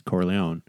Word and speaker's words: Corleone: it's Corleone.